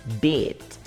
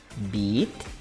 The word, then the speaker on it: beat: Só que, ó, a, a pronúncia dele, dessa palavrinha aqui, é bit. E a praia é beach. Então, ó. Beat, beach. Olha o finalzinho que diferença. Beat, beach. Ok? Fica claro aí pra você não errar a pronúncia, hein?